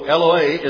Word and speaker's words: Eloah: Though it is the plural of Eloah, E-L-O-A-H, that's how we would pronounce it out in English, at least the way it is, I believe, in Strong's Concordance.